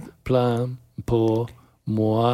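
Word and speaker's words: Ça plane pour moi: Ça, plan pour moi.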